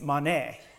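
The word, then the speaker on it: mone (μονή): It's mone, and that's spelled M-O-N-E.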